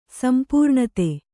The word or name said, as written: ಸಂಪೂರ್ಣತೆ